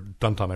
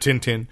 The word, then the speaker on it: Tintin: Albums were a little larger, but like Asterix, the Gauls, you know, or Tintin, everything, or Duntan, I've heard it pronounced that way, but Tintin, you know, you get these solid storylines and now that's how we consume old Uncle Scrooge stories too.